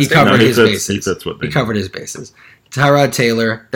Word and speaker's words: Tyrod: There has been debate on how to pronounce his name this last week. – Tyrod. – I read from another source that his agent and father both addressed him as Tyrod to this particular source.